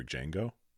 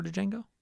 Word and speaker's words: Django: And I can't say, was that before Django? – Yes. It was. I believe the D is silent. – It is.